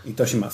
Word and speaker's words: Itoshima: Sorry. I said it wrong. Yeah. I was saying Itoshima, but... Itoshima.